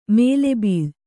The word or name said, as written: ಮೌಲಬಲ